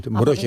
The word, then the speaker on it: Mrożek: Mrożek se escribe M-R-O-Z-E-K. Esa Z se lleva un puntito en polaco y es como Y. – Mrożek.